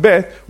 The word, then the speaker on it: Beth: For example, a point or a hook at the edge of a Hebrew letter B, pronounced Beth, would change it to a K, pronounced Kaf.